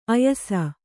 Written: ಆಯಾಸ

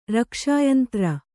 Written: ರಕ್ಷಾಯಂತ್ರ

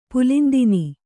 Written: ಪುಲಿಂದಿನಿ

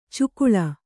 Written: ಚಕ್ಕಳೆ

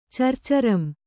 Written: ಚರ್ಚರಂ

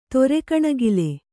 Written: ತೊರೆಕಣಗಿಲೆ

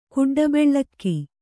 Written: ಕುಡ್ಡಬೆಳ್ಳಕ್ಕಿ

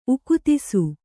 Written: ಉಕ್ತಿಸು

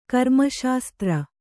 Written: ಕರ್ಮಶಾಸ್ತ್ರ

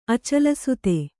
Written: ಅಚಲಸುತೆ

